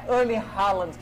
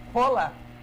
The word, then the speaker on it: Haaland: Porque não é Erling Haaland que fala o nome dele. – Como é que fala? – Rola. Erling Rola.